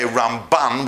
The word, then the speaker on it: Ramban: Now, we don't get confused between the Rambam and the Ramban, because the Rambam, you put the emphasis on the first syllable, and when we say Ramban, we put it, as apart from changing M to N at the end, we put it on the second syllable.